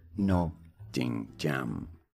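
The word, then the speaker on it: Ngô Đình Diệm: The name is Ngo Dinh Diem. It is spelled NGO space DINH space DIEM.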